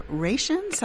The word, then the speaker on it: Raetians: And the Raetians, I think is how you pronounce it. And these were alpine tribes. – R-A-C-I-A-N-S? – R-A-E-T-I-A-N-S.